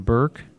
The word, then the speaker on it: Bierk: You and Jason Burke sing the song Every Day, B-I-E-R-K.